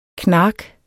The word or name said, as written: knark